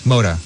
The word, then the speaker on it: Mota: We've had our guest tonight, Isabel Mata or Mota. How do you prefer it to be pronounced? – Mota. – Yeah. So I was, okay. The third version.